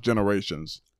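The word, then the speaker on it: Generation: The T looks like a plus sign. – Yeah, but it's pronounced Generations.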